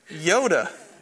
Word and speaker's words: Joda: I've highlighted this name, J-O-D-A. You know, in Hebrew, there was no letter J. It's actually a Y, right? Yoda. Yoda is how you would pronounce that name in Hebrew.